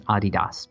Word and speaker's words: Adidas: But that's the reason in Europe, mostly, it's known as Adidas, because that is the pronunciation, Adi Dassler.